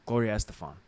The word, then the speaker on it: Gloria Estefan: Then it was the Blues Brothers, Boyz II Men, Gloria Estefan. Why did I say it like that? Gloria Estefan.